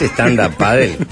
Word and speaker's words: stand-up paddle: ¿Practicás stand-up paddle? Buah. – No sé si se dice así. – No, se dice así, sí, obvio. Bien.